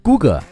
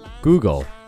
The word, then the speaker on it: Google: 它其实正确的读音应该是 Google、 Google。前面的 Go 很简单，后面一个音节注意一下，它不要读成 Go，也不是 Go，而是一个 G 加一个含糊的舌边音，